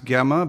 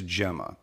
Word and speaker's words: Gemma: Sometimes Gemma, but we learned the pronunciation is Gemma.